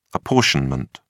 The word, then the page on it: apportionment: British English